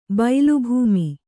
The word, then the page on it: ಬೈಲುಭೂಮಿ: bailu bhūmi